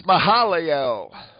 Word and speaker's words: Mahalalel: Adam, Seth, Enosh, Kenan, Mahalah. How to pronounce that one? Jared, Enosh... – That's Mahalalel. – Mahalalel? – Mahalalel. Mahalalel. That's his name.